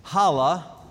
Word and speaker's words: challah: Sunset Friday until night on Saturday, challah, a silent C there,